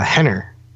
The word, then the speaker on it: Renner: Um, just like the R's are pronounced H's, like like uh, Henner, which is R E N N E R, if it's at the beginning of the sentence.